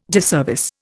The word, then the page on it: disservice: Indian